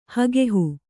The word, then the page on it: ಹಗೆಹು: Kannada